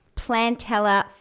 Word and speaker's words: Plantella: And that is why we've got Rob and Nicholas from Sheoak Design in the studio tonight to tell us about Plantella Festival. I hope I pronounced that correctly. – Nutella, but with plants.